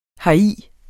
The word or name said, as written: heri